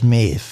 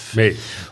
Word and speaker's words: Maeve: Oh, wie spricht sich die aus? Maeve. Maeve.